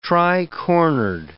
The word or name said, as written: tricornered